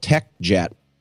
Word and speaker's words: TechJect: It's called TechJect, I think that's how you pronounce it. It's it's T E C H and then J E C T,